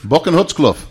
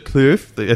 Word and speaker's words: Boekenhoutskloof: Boekenhoutskloof. – Boekenhoutskloof. Winery. No, you got to get the Kloof. I think that impressed you. – Yeah, Kloof. And your pronunciation is perfect.